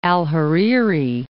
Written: Hariri, al-

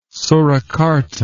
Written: Surakarta

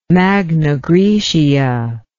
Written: magna graecia

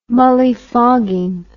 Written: mullyfogging

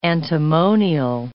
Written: antimonial

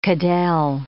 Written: cadelle

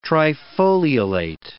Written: trifoliolate

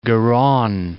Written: Garonne

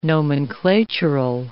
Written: nomenclatural

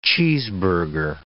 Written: cheeseburger

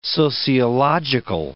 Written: sociological

